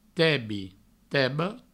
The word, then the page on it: tebi: provençal; teba; tebo